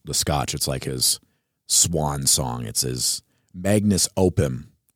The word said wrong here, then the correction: magnum opus: Magnus opus. I think so. Yep. – Pronunciation. Magnum opus. Magnum opus.